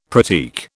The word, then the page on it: pratique: British English